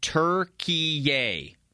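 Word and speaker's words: Türkiye: And the way you pronounce it is Turkey-ia. Turkey-ia. Turkey-ia. Not Turkey.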